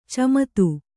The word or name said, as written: ಚಮತು